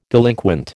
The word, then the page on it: delinquent: American English